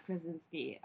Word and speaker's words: Krasinski: But I do love that Emily Blunt and John Krasinski are married, and they're starring together.